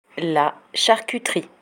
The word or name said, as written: la charcuterie